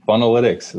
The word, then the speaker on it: Funnelytics: Did I say that right? Funnelytics? – Funnelytics, that's right.